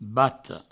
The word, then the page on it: bata: Provençal; bato